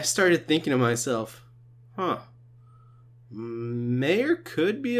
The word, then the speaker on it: Meyer: We didn't even talk about the possibility of Marcelo Meyer or... Meyer is how you pronounce it. My bad.